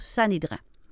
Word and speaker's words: Sanhédrin: Hein, ça s'écrit avec S-A-N-H-E accent aigu, D-R-I-N, mais ça se prononce Sanhédrin.